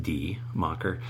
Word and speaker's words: Die Macher: As soon as I heard about D-Mocker, spelled D-I-E, but pronounced D-Mocker, I wanted to play it.